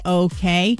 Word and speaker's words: hotteok: They say that this cookie is inspired by something called the Hatuk. Is that how you pronounce it? Hotteok, a popular Korean street food pancake with caramelized brown sugar flavors.